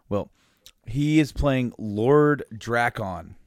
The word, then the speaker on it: Drakkon: It's Drakkon. – I would say Lord Drakon.